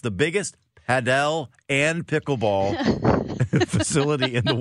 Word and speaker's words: Padel: For Roaming St. Louis, Paddle and Pickle Club? – Padel.